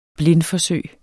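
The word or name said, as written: BLINDFORSØGENES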